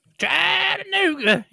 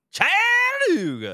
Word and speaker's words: Chattanooga: Chattanooga. You got to say it like that. – Chattanooga.